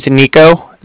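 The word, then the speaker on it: Niko: So, my name's Nico. That's N-I-K-O. And I'm calling from the Bank of Cardiff, and that's spelled C-A-R-D-I-F-F, as in Frank Frank.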